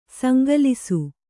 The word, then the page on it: ಸಂಕಲಿಸು: sankalisu